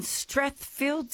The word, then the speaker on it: Strathfield: Leanne is in Strathfield, say.